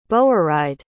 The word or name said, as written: bourride